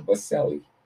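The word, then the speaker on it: Boselli: Baselli.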